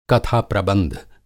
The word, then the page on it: कथाप्रबंध: Hindi